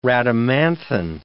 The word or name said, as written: rhadamanthine